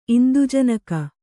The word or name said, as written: ಇಂದುಜನಕ